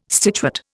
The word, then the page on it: stitchwort: Indian